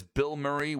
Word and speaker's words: Bilmuri: That is Bill Murray with A Day to Remember. The band, not the actor. It's spelled B-I-L-M-U-R-I.